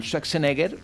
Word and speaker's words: Schwarzenegger: Sí, es una versión de esa gran película dirigida por James Cameron con el mazas Arnold Schwarzenegger y la magnífica Linda Hamilton.